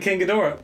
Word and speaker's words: King Ghidorah: Dora? – King... – Dora the Explorer. – King Ghidorah. Oh, you pronounced it wrong.